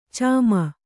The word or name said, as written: ಚಿಮ್ಮಿ